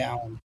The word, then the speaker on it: Alain: So, but I've lived here so long and I have my first name butchered so long that I just ask people just call me Alain.